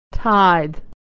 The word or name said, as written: tithe